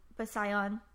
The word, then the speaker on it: Bisayan: Like Bisayak or is that right? Is that how I said that right? Bisayan.